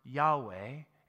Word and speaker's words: Yahweh: And that word I am is in Hebrew is pronounced Yahweh.